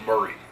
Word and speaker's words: Maury: People get pretty excited at Maury, which is pronounced Murray.